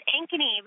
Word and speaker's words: Ankeny: Did I pronounce that right? – It's Ankeny, but good morning.